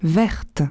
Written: vertes

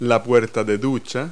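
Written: shower door

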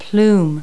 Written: plume

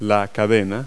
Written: chain

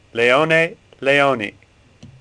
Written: Leoni

